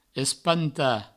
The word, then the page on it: espantar: provençal; espanta